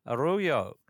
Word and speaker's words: Araujo: Welcome to The Grass is Greener, Felipe Arroyo.